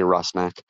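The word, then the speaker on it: Rusnák: By the way, it's very refreshing to hear somebody also call it, say, Rusnak. – it took a few episodes. – No, we got to say it right.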